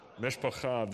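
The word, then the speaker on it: Mishpacha (משפחה): Mishpacha, that's right. And one of the ways that we say community in Hebrew is kehilah, which also means congregation. So two good words to know, mishpacha, say that with me, and kehilah.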